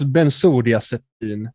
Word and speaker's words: bensodiazepin: Jag, jag det, det, det, det uttalas bensodiazepin. Jag googlade på det nu.